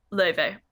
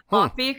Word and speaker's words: Laufey: And in mythology books in English, it's also Leve. But it looks like Luffy because it's spelled L-A-U-F-E-Y.